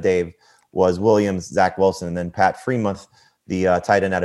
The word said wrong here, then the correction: Freiermuth: I said his name wrong, didn't I? Freiermuth.